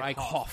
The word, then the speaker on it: Eichhoff: How do you spell it? Eichhoff? Or Eichhoff? – Eichhoff. – Yeah, Eichhoff. Like, I cough when Diaz? – I watch this guy.